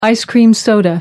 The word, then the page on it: ice-cream soda: American English